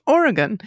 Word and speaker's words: Oregon: It's pronounced Oregon. Say it fast so it doesn't sound stupid.